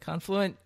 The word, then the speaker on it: Confluent: So it's Confluent. Am I saying that right? Confluent? – Yeah.